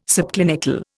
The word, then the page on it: subclinical: Indian